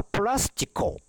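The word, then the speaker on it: プ ラ ス チ コ (πλαστικό): で、これ、プラスチックって日本語では言うんですけど、日本では言うんですけど、ギリシャ語での正しい発音は、プラスチコだそうです。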